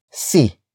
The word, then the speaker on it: si: And then we have C, which is spelled as S-I.